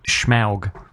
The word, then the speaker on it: Smaug: Apparently, Peter Jackson called it Smaug. He pronounces it Smaug. – Yeah, Smaug. – Yeah, I think in the movie. And in the trailer, it's pronounced as Smaug, and I think that's just silly. I'm like, Smaug. – Smaug. I still think of it as Smug, actually.